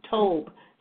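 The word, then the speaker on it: tob (טוב): But then we also find that out that the Hebrew word for good is the word tub, spelled T-U-B, but it's pronounced tob, T-O-O-B.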